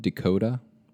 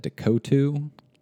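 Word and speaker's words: DeCoteau: David Dakota. – I thought that's how you said it. – No, it's spelled weird, so I said Dakotu.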